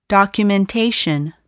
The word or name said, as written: documentation